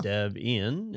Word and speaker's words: Debian: This is, 30 years of, is it Debian? Is that how you say it? – It's Debian. – Debian. Okay, yep. That is the correct way. – It is, yes.